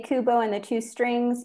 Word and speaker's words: Kubo: So A, Kubo and the Two Strings.